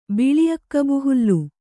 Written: ಬಿಳಿಅಕ್ಕಬುಹುಲ್ಲು

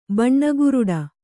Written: ಬಣ್ಣಗುರುಡಿ

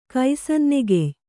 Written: ಕೈಸನ್ನೆಗೆಯ್

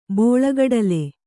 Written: ಬೋಳಗಡಲೆ

